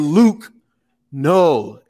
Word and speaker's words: Luke Null: We got my man Luke Null.